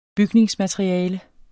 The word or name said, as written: bygningsmateriale